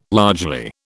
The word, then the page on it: largely: British English